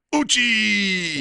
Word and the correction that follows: Uchi: Which we you don't say it like that. – How do you say it? – Uchi. – Uchi.